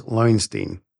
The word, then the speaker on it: Loewenstein: Well, anyway, talking about great names, today I'm joined by Liz Loewenstein.